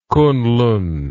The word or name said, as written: Kuenlun